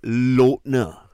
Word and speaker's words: Lautner: But so it's not Taylor Lautner. It's Lautner. So it's L-O-W. Say it like how you say low.